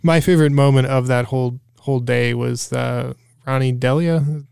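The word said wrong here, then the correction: Deila: Is that how you pronounce it? – Dyla, I think. – Dyla, it is.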